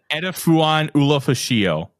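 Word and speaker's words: Edefuan Ulofoshio: Edifuan Ulufashio.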